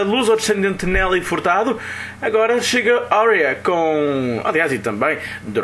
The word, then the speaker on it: Áurea: Áurea, com pronúncia inglesa Áurea, traz-nos uma melodia do seu primeiro trabalho.